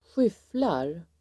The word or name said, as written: skyfflar